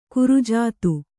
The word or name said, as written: ಕುರುಜಾತು